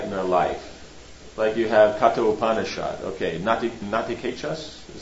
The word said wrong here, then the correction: Nachiketas: is that how you say it? Natikechas. Nachiketas, yeah.